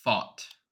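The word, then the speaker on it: Pfaadt: Yeah, so it's the pronunciation is Fought.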